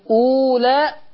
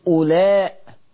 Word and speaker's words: أولاء: الصفر المستدير ده ما يوضع على حرف لا ينطق، فلما نيجي نبص الكلمة زي كده ها أنتم أولى خطأ. من قال لك أنطق الواو؟ ها أنتم أولاء، أولاء من غير ما تنطق الواو، هذه واو لا تنطق، وضع عليها الصفر المستدير ولا تنطق.